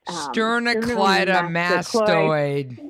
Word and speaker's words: sternocleidomastoid: Sternocleidomastoid.